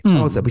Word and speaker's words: Xhosa: I typed in Koza, K-O-S-A, into the computer. We've got X-H-O-S-A, which is a Oza. It's pronounced with a click, Oza, which is a tribe.